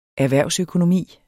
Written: erhvervsøkonomi

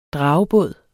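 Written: DRAGEBÅDENE

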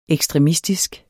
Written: ekstremistisk

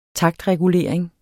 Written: taktregulering

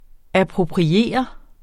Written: appropriere

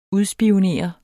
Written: UDSPIONEREDE

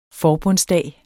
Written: forbundsdag

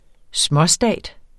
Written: småstat

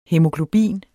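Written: HÆMOGLOBIN

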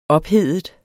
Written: ophedet

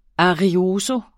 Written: arioso

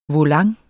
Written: volant

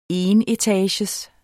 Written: enetages

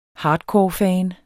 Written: hardcorefan

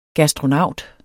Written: gastronaut

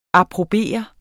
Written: APPROBERENDE

